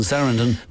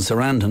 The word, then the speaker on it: Sarandon: her surname, it rhymes with abandon. So, like, rather than say Susan Sarandon, people had to say Susan Sarandon.